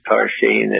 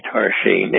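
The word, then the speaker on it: Tarxien: So there are a lot of X's when you see Maltese, but they're pronounced like S's. So it's Tarshin, is spelled with T-A-X-X-A-R-E-N-E, Tarshin.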